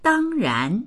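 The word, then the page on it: dang1ran2: Mandarin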